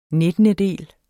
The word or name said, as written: NITTENDEDELE